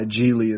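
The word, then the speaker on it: Gigli: It's G-I-G-L-I. Yeah. Geely is how it's pronounced, though, for some reason.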